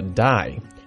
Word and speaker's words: dye: 这里一个核心单词叫做 dye, dye。啊，这个 die 不是死的意思吗？诶，它的发音跟 die, die, 死是一个同音词，但是它的拼写不一样，它是 dye, die。